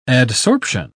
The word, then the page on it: adsorption: British English